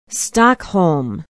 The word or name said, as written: stockholm